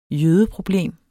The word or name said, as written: jødeproblem